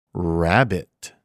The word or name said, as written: Rabbit